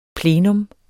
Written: plenum

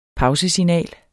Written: pausesignal

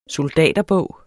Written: soldaterbog

